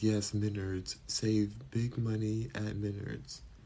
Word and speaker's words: Menards: You said Menards. Menards is how you pronounce it.